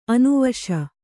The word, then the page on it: ಅನುವಶೆ: anuvaśe